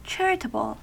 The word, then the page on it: charitable: American English